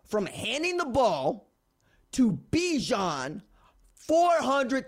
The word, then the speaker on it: Bijan: Bijan rhymes with Dijon because my man is all sauce.